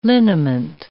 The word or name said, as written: liniment